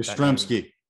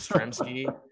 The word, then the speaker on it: Yastrzemski: So I think they're still in, you know, Yastrzema, I get Yastrzemski. – that. – Yastrzemski.